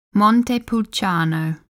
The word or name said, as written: Montepulciano